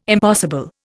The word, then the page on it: impossible: Indian